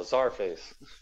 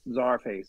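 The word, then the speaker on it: Czarface: I really like, I like what you did with Caesar Face. – Oh, the Czar Face. – Czar Face, yes. – Yeah, Czar. – I always say that wrong.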